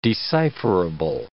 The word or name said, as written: decipherable